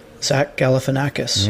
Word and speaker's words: Galifianakis: Zach Galifianakis. – Yeah, all right. – Zach Galifianakis. – Yeah, okay, I get Zach Galifianakis. – All right, I get the message. It's Zach Galifianakis.